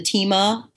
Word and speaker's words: Thieme: The Tema Atlas, it's silver and blue. It's spelled T-H-I-E-M-E.